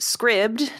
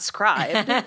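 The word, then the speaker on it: Scribd: I learned that it's pronounced scribbed, not scribed.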